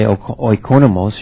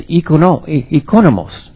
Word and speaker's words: oikonomos: Now, I have a problem in my house because my wife is Greek, and if I say oikonomos, she says, that's not the way you pronounce that. It's oikonomos, not oikonomos.